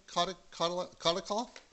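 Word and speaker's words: Kotlikoff: Is that how you say it? Kotelikoff? – Close enough.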